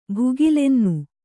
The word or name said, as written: ಭುಗಿಲೆನ್ನು